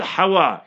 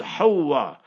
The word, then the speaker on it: Hawwa: The word is not Hawa, it's Hawa. Tajdeed on the wow. H-A-W-W-A.